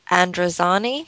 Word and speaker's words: Androzani: The Caves of Androzani, mm-hmm.